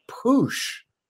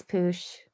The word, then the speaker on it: Poosh: Kourtney Kardashian and Poosh. Am I saying that right, Kourtney? How are you? – Hi, Wesley. Yes, you're saying that right. It's Poosh.